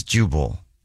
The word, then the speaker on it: Jubal: My name is Jubal. – Jubal. – Yes. – How do you spell that? – J-U-B-A-L. – There wasn't any Jubal there when I went last time, but I'm on my way over.